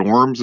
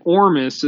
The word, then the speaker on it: ORMES: You say it Ormis is how it's pronounced,